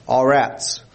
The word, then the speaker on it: arats: That is the word A-R-A-T-S. And it's pronounced all rats.